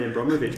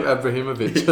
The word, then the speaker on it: Abramovich: You mispronounced Roman Abramovich before. You chucked an extra syllable in there. – It became Abrahimovich, I think.